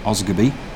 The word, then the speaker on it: Asgabeir: It's spelt A-S-G-A-B-E-I-R. Guess how it's pronounced? Yep, you've got it. Osgoby.